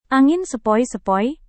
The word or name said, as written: angin sepoi-sepoi